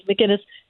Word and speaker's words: McInnes: It sounds like the beer Guinness, but it's McInnes.